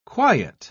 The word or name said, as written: quiet